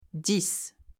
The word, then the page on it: 10: French